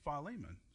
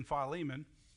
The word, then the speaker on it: Philemon: And by the way, some people pronounce it Philemon. I won't argue with you if you want to pronounce it Philemon. I just pronounce it Philemon. That's the way I was always raised.